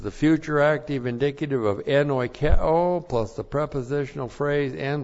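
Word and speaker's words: En oikeo (ἐνοικέω): Well, first of all, we have enoikeo. En oikeo. E-N-O-I-K-E long O. En oikeo.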